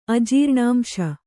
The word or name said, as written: ಅಜೀರ್ಣಾಂಶ